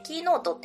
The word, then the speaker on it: 音符: こちらはですね、キーノートっていうのは音符って書いてキーノートと読みます。